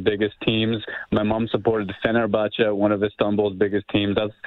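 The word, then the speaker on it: Fenerbahçe: And they're Fenerbahce, local, yeah.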